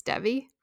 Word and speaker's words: Devi: How do you say it? Do you think Devi Harris? It's Devi. – I think so.